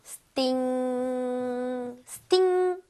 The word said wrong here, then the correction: stink: Stink. 맞습니다.